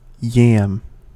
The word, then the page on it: yam: American English